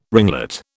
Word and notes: ringlet: British English

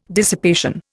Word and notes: dissipation: Indian

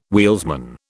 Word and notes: wheelsman: British English

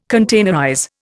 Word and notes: containerize: Indian

